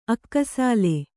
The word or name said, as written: ಅಕ್ಕಸಾಲ